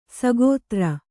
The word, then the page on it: ಸಗೋತ್ರ: sagōtra